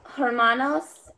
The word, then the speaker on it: hermanos: Oh, estos son hermanos en el familia. – Mm, son dos. Eh, so remember, herman, H is silent.